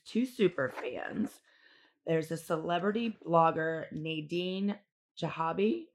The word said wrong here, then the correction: Rajabi: Am I saying that right? Jahabi? – Rajabi, I think. – Rajabi and comic Lisa Arch.